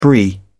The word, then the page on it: brie: British English